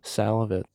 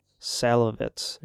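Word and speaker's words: Szalavitz: Salavitz. – Salavitz. Yeah. That's S-Z-A-L-A-V-I-T-Z.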